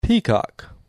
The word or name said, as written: Peacock